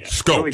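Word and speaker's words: Schoop: What are we, it's not Scoop. What do we call it? – Scope. Jonathan Scope.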